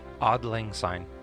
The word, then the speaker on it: Auld Lang Syne: Auld Lang Syne.